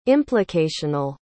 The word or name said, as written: implicational